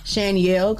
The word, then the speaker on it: Shanielle: People even try and put Y in Shanielle because it sounds like a Yale, but it's spelled like Danielle. – Right. – But an S-H.